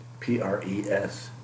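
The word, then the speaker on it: presbyteros (πρεσβύτερος): Secondly is the representative church government, and this is the Greek word presbyteros, presbyteros. Did I say that right? – You said it right, but would you spell it again? – presbyteros. E-R-O-S, presbyteros.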